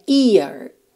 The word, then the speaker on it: ear: Year, essa palavra aqui, ano, em inglês, é interessante só fazer uma leve diferenciação entre a orelha, que é ear, ear, tá? Você tem realmente esse I, esse ataque direto nesse som, ear. Se escreve é a R, não tem o Y no começo, tá?